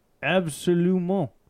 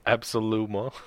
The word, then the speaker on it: absolument: And I was already pretty fucked up and I was like, absolument.